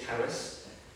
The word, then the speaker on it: Charis: because my niece is called Caris, which is, or Caris, I think it would be the stress on the second syllable if we're in Greek, but she's called Caris with a K, some people write it with CH.